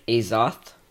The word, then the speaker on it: Azoth: Okay, so I'm going to start with Forms, Ishrakion, Traditionalists, Holy Vem, Electromagnetic Azoth, is that how you pronounce it? – Yep.